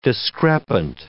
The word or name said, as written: discrepant